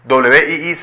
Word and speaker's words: Wii: WII se pronuncia Wii, y la consola también es conocida por su nombre código Revolution.